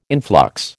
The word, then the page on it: influx: American English